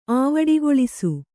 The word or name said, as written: ಆವಡಿಗೊಳಿಸು